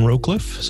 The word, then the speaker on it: Rowcliffe: I do write about mostly Canadian politics, but also some British, New Zealand, and Australian politics at Devin Rowcliffe. So Rowcliffe is R-O-W-C-L-I-F-F-E.